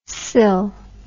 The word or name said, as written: cill